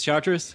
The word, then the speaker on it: Chartres: I'm James Chartres.